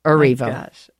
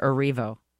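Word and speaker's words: Erivo: And she was there comforting, and the big meme over the weekend was, everybody deserves a friend like Cynthia in Revo. – Arrivo. – Arrivo? How do you say it? – Cynthia Arrivo.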